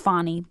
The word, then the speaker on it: Fani: Against Fani, I know it's pronounced Fani,